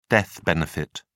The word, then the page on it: death benefit: British English